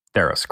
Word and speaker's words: Theros: Mythical Odysseys of Theros. Did I pronounce yes. that right? – Theros, correctly.